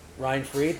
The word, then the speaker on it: Reinfried: Uh, and I'm with Kyle Reinfried.